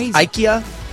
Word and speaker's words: IKEA: It's pronounced IKEA.